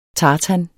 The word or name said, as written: tartan